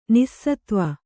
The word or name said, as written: ನಿಸ್ಸತ್ವೆ